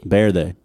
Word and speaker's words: Verde: Well, you kind of said it correctly in Spanish, because the V in Spanish is more like a B sound. – Really? – Verde.